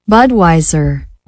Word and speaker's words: Budweiser: Budweiser, ¿sí se pronuncia así? Compa, a ver, déjame buscar el traductor, se me hace que no. Espérate. Acá que estamos platicando, déjame corregir esto. Pegamos acá, ejecutamos. 1, 2, 3.